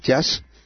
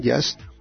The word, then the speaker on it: YaST: todo eso lo viene incluido dentro de la herramienta llamada JAS, que se escribe YAST, JAST.